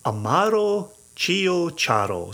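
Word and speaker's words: Ciociaro: So it's Amaro, A-M-A-R-O, separately. Chio, C-I-O, separate, C-I-A-R-O. Amaro Chiocharo. And if you can start to say it like that, you already sound like you know what you're doing.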